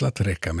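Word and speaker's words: La Treccha: Trecca stavas T-R-E-C-C-H-A. Ordet är 1600-tals italienska och uttalas La Trecca, men det kan vara en gammal stavning av La Treccia, flätan.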